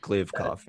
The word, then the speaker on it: Kliavkoff: Kliavkoff. Kliavkoff, yeah.